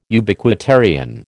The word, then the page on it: Ubiquitarian: American English